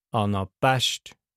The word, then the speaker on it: Anapest: Beim Daktylus und beim Anapest erklärt die Aussprache die Bedeutung. Beim Daktylus Daktylus, beim Anapest Anapest. Harmonie ist ein Anapest.